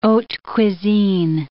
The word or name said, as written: haute cuisine